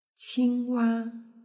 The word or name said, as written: qingwa